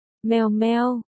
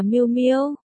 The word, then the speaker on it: Miêu Miêu: Mèo mèo, Lôi Kỳ vẻ mặt nghi vấn. Không phải, là Miêu Miêu, ta gọi là Diệp Miêu.